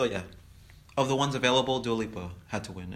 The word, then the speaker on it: Dua Lipa: And I hope I'm not pronouncing her name wrong. – Oh, it's funny because Wendy Williams started this trend of Dula Peep. So you're fine with her name pronunciation.